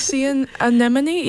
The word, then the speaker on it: sea anemone: But this next one, Blue Dino, see at anemone, is that how you say it? – Sea anemone? Yeah, that sounds right.